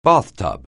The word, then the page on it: bathtub: anglais